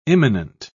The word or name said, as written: imminent